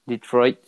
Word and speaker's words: Detroit: Detroit.